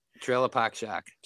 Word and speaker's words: Trailer Park Shark: Trailer Park Shark.